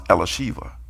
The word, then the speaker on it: Elisheva: I mean, you know, my daughter Elizabeth, her name in Hebrew is pronounced Elisheva. It's beautiful.